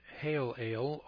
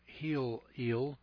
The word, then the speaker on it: Helel: This was taken over from the Latin Vulgate, and many scholars prefer to use other words, which more correctly translate the Hebrew Hallel, spelled H-E-L-E-L, pronounced hail ale or heel eel.